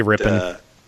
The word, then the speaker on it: Rippon: Like Adam Rippon or Rippon or Groupon. – Yeah. – It's Rip-on. Closer to Groupon. Closer to Groupon. It's Rip-on. But I'll still say Rippon.